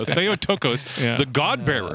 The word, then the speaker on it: Theotokos: Which is why we can call her the Theotokos.